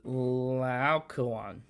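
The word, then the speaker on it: Laocoön: How do you pronounce it? – Laocoon. – Close. Passable.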